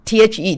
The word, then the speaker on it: the: This is life and this is the THE 定冠词，加在母音前面叫念成 the， This is the eternal life，这就是永生啊，永恒的生命啊，那个是一种品质的生命，是基督的生命啊。